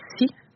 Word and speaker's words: Assi: Puis Assi, c'est le mot, ça s'écrit A-S-S-I, c'est le mot pour terre dans la langue Innu-Imun. Mais chez moi, dans le dialecte, on va le prononcer Si. Fait que c'est vraiment comme le mot en anglais pour la mer,